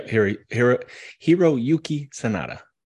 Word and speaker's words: Hiroyuki Sanada: I guess there was one that was not comedic that was a really fun one to watch was Hiroyuka Sanada and Michael Shannon. I think that's how you say his name. – Yeah. Yeah. Hiroyuki Sanada.